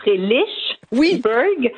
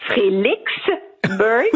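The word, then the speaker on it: Frelighsburg: Mais je dois vous dire que moi-même, quand, ben, en fait, même ici, dans la communauté, on ne s'entend pas sur comment le prononcer. Alors, il y a le Frelichberg, oui. – Frelichberg, oui. Et beaucoup disent Frély, tout simplement.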